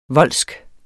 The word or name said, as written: voldsk